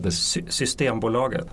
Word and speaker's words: Systembolaget: Systembolaget.